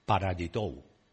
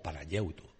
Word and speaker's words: Panayiotou: Nació en Londres un día de junio con un nombre difícil de pronunciar, Georgios Kyriakos Panayiotou, o Panayiotou, como queráis.